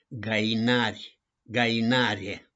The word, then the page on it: GAINARI: GAINARIE